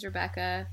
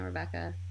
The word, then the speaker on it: Rebecca: My name's Rebecca, which is spelled like, you know, Rebecca.